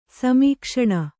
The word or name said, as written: ಸಮೀಕ್ಷಣ